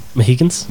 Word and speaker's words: Mohicans: Last of the Monohickians. – Yeah, I'll let you keep that one. Yep. There. – How would you pronounce it? – Mohicans. – Mohicans.